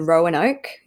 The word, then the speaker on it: Roanoke: Anyway, so on March 14, so 4 days after they died, Tracy Cooper from the medical examiner's office in Roanoke, is that how you say it? – Yep.